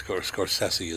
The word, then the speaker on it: Scorsese: I was debating how to say it with my wife before. – I've always heard Scorsese. – But yeah, everybody says Scorsese. It's Scorsese is how you say his name.